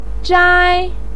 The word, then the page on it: zhai: 1